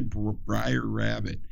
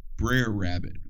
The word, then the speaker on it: Brer Rabbit: How do you say Briar Rabbit? No, baby girl, that's Brer Rabbit.